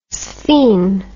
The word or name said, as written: sphen